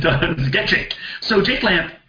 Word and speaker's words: Lampe: I'm assuming the name's pronounced Lamp, L-A-M-P-E, so maybe Lamp.